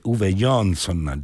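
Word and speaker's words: Uwe Johnson: Vi ricordo il libro di cui abbiamo parlato, in uscita presso l'Orma Editore, Uwe Jonsson, giustamente un ascoltatore mi ha corretto la pronuncia,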